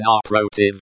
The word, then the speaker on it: напротив: Pronounced напротив, on the second syllable.